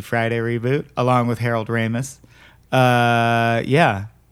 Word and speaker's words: Ramis: I need to know if you're being cute or if you think it's pronounced Ramey. – Oh, Ramis? – Yeah.